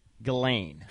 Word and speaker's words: Ghislaine: I think they pronounce it Ghislaine.